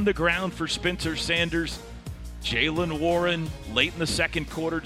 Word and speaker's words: Jaylen Warren: Jaylen Warren. – Jaylen Warren.